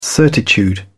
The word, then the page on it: certitude: British English